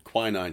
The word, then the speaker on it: quinine: She gave him some quinine powder. I don't know how it's pronounced. – I was always told quinine because my mother took quinine.